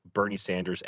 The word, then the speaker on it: Bernie Sanders: I love Bernie Sanders' anger.